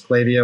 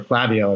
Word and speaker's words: Klaviyo: Regardless of how you say it, whether it's Klaviyo or Klaviyo, I've heard it multiple different ways.